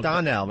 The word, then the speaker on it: Donnell: Darnell, or how do you say his name? – Donnell, right? – Donnell, yeah.